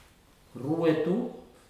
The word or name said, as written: rueto